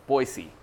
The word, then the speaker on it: Boise: Everybody there pronounces it Boise.